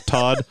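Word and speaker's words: Tod: T-O-H-T, mostly just pronounced by Todd, like Todd.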